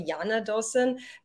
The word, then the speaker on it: Jana Dawson: So my name is actually Jana Dawson. J is pronounced like a Y because I happen to be Croatian.